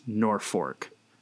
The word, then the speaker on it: Norfolk: It's named after the North Fork of the Platte River, so it is pronounced Norfolk. No stress syllables.